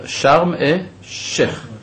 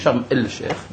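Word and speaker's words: שרם אה שייך (شرم الشيخ): אבל אני אומר, שרם אה שייך, נכון? לא אומרים את הלמד. לא אומרים שרם אל שייך, למרות שיש למד, אבל לא אומרים אותו. נכון? לא מבטאים אותה, ובמקומה יש דגש בשין.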